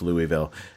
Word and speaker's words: Louisville: It's Louisville. – Oh, I should know better. That's true. – Right? Like Louisville. New Jersey, Louisville.